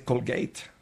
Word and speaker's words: Colgate: Bueno, no se dice Colgate, se dice Colgate.